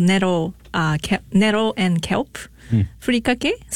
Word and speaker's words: furikake: The furikake, is that how you pronounce it? – Yes, furikake, the dry seasoning.